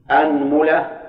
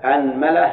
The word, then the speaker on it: أنملة: طيب همزة أنملة ثلث يعني معناه ننطق به بالحركات الثلاث، الفتح والكسر والضم. وثالثة وش ثالث أنملة؟ الميم. أيضا انطق به بثلاث حركات بس ثلاثة ثلاث، ثلاثة في ثلاثة تسع ولا لا؟ طيب نشوف الهمزة الآن، الفتحة قصد، نفتح الهمزة ونجيب ثلاث في الميم. أنملة أنملة أنملة هذه ثلاث، نضم الهمزة ونأتي بثلاث في الميم.